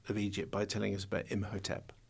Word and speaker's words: Imhotep: How are you saying Imhotep? – Imhotep, So we pronounce the H.